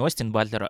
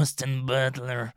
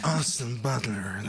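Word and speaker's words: Austin Butler: Только на самом деле, правильно произносить не Остин Батлер, а Остин Батлер. – Остин Батлер.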